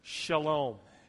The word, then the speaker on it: shalom: It's a Hebrew word, shalom.